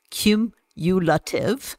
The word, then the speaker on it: cumulative: Cumulative. Is that the right way to pronounce it, Rach? – Yeah.